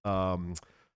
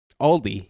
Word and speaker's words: Aldi: Just like you dummies in it, you weirdos in the Midwest also pronounce Aldi's. – Aldi. – It's Aldi. – It's Aldi. – There is no S at the end of it.